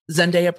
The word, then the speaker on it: Zendaya: And he says Zendaya has been acting since the Disney Channel and she goes by one name, and said Zendaya, not Zendaya, Zendaya. I went and I scoured the internet. It didn't take long. Zendaya pronounces her name Zendaya. All of the attorneys in this case pronounced her name Zendaya. It's Zendaya.